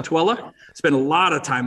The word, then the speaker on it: Tooele: Hey, that's impressive that you know how to pronounce Tooele, because for those of at home, it's T-O-O-E-L-E. So write that down and try to pronounce it, T-O-O-E-L-E. You know if you've got Utah credentials if you pronounce Tooele right.